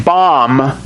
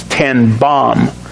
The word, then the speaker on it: Ten Boom: ten bom, the way it's pronounced in the Netherlands, ten bom.